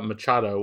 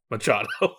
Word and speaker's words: Machado: And we should note, when Ryder said Carmen Maria Machado, what he meant to say was Carmen Maria Machado.